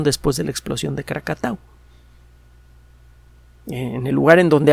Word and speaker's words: Krakatau: En 1883, en la noche del 26 al 27 de agosto, están cumpliendo eh, años de, esta erupción famosa, el volcán Krakatau, mal pronunciado Krakatoa,